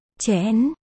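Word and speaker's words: Chén (陈): Trong tiếng Trung. Trình. Đọc là. Chén. Trần. Đọc là. Chén.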